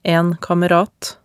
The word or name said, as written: en kamerat